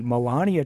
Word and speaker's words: Melania: So I think the first big story is to talk about our First Lady, Melania Trump. Did I pronounce that correctly, first of all? – Good job. – All right, because I pronounced it wrong like four times in the hallway, and I had to be corrected.